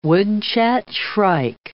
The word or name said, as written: woodchat shrike